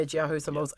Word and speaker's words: Jiahu Symbols: Um, and you said the name right, so thank yeah, Jiahu Symbols.